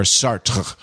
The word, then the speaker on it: Sartre: You're supposed to kind of hairball out the final R-E, so it's like joie de vivre. A French Canadian professor of mine once said you pronounce the R but not the E, so like joie de vivre or Sartre.